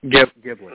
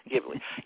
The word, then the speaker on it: Ghibli: Now, how do we want to pronounce this? I keep hearing it pronounced three or four different ways. So, it's Studio... – I say what did we decide it was called? – Ghibli. – Mm-hmm. Ghibli. Yep, I've always said Ghibli.